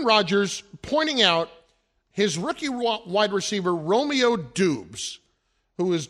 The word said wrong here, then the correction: Doubs: doing, quote, Romeo Dobbs, it's pronounced, D-O-U-B-S, yeah. – pronounced that way.